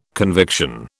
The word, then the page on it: conviction: British English